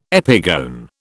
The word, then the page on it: epigone: British English